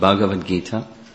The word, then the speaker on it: Bhagavad Gita: But that's how it's pronounced, Bhagavad Gita.